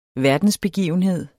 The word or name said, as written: verdensbegivenhed